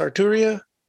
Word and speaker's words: Arturia: Is that what it? How you say it? It's Arturia.